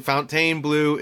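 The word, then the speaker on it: Fontainebleau: and the only thing Fontaine Blue is for the French. You know, there's a city called Fontaine, so that's what everybody assumes. It's Fountain. Call Miami. Don't comment below and say we're saying it wrong.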